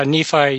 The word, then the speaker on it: Nephi: Yeah, it's actually pronounced Nephi, I believe. – Okay. – Yeah, Nephi, Utah.